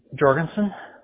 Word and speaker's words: Jorgensen: Jorgensen. So J-O-R-G-E-N-S-E-N. – J-O-R-D-E-N-S-O-N? – G.